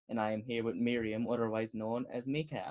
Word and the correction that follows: Micah: Am I pronouncing that right? Close, Micah. It's Micah.